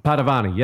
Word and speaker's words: Padovani: Henry Padovani. – Padovani.